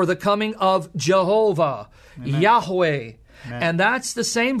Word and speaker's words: Yahweh: In Hebrew, Yod, Hey, Vav, Hey, where we get the divine name. Some pronounce it Yahovah, some Jehovah, Yahweh, however you want to pronounce it.